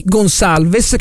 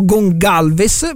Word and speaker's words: Gonçalves: al centro della tre quarti Gonçalves, che qui è stato ribattezzato Gongalves, però insomma si scrive con la cicedigliata,